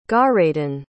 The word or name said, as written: garadyn